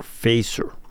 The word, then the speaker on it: Feser: And the last name is spelled F-E-S-E-R, Facer.